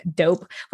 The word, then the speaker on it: Doughp: First of all, I really like your company name, Doughp, which is like pronounced like that's dope, but it's spelled the way it is, D-O-U-G-H-P.